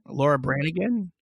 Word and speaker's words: Laura Branigan: I think that's how you say it. Laura Branigan. Sounds about right.